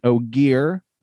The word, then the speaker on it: Ogier: He is an Ogier, not an ogre.